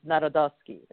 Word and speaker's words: Narodowski: That is, yep, and then there's a Frank Narodowski, and that was, the correct pronunciation of my last name is Narodowski. It's not Narodowski.